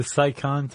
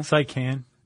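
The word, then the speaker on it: Seikan: The Saikan Tunnel. – The Saikan Tunnel? – Saikan.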